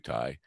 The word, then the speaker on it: Tye: We'll give him a little test and then we'll play a quick commercial and meet Timothy Ty, Midnight Sky. Everything is rhymes with guy.